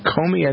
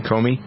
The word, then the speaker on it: Comey: The latest, this is interesting, in the first major policy speech, the new director of the FBI, James, is it Comey? I think that's it, Kenny, right? Comey? How you pronounce his name? C-O-M-E-Y.